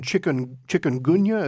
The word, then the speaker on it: chikungunya: You had mentioned chikungunya. Is that how it's pronounced? – Yes.